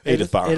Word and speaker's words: Edinburgh: Edinburgh. Is that how you pronounce it? – Edinburgh. – Edinburgh. Yep.